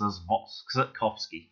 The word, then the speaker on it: Zatkowski: It's Robert, so Rob, Alex, and I'm not even sure how you pronounce that last name, Zatkowski. And I'm going to go with my best Polish pronunciation. There's a W in there, but I'm going to pronounce it as V. So Robert Alex Zatkowski.